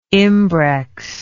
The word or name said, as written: imbrex